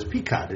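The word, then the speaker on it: Picotte: Joe Sterita wrote it, and I thought, oh, who's this Susan LaFlesh Picot? And I had to find out what the exact pronunciation is from talking to Mr. Sterita, but it's Picot. It's a French name.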